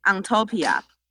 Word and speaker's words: UnTOPIA: 我们这个活动的名称，嗯，英文的话，我们自动把它念成 Ontopia。 – Ontopia。 – 对，就是这整个展览的名字叫做 Ontopia，不存在的理想与存在的社会。 – 是，这是整个展览的主标题。 Ontopia 其实这个词来自于当初我们原本要作为高三那年毕业公演的剧名候选。